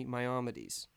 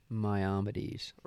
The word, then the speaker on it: Maimonides: Maimonides. – Maimonides.